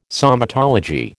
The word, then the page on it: somatology: American English